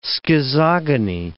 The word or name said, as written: schizogony